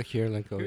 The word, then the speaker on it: Kurylenko: can't remember how to pronounce her name. Olga Kurylenko? Kurylenko? – Olga Kurylenko.